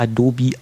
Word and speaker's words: Adobe: Und ich hatte mal vor längerer Zeit ein Interview geführt mit jemandem von Adobe und es wird offiziell auch Adobe ausgesprochen. Also das ist die richtige Aussprache, also als kleinen Service von unserer Seite.